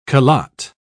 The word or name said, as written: calotte